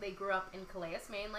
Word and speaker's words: Calais: Andrea is an American poet and activist, originally from, I might mispronounce this, Calais, Maine? – Sure, yes, that's correct. Yep, Calais, Maine.